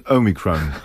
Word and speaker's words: Omicron: In the UK, it tends to be Omicron. It's a short O. That's what it represents. It's not a long O sound. It's a short O. Omicron, like micro, is small.